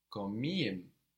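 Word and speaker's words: komiğim: I'm funny is comiem. Comiem. It's spelled K-O-M-I. And then the soft G with the little upside down point on it.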